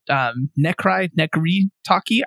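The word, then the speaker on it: Necry Talkie: There's a band called Necri Talkie. I don't know how to say their name. Talkie, like walkie-talkie for that second word. And the first one is N-E-C-R-Y.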